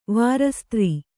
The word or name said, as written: ವಾರಸುದಾರ